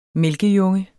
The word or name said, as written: mælkejunge